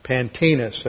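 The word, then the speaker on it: Pantaenus: Pantinus, that's spelled Pantaeus, Latin form, who died about AD 190.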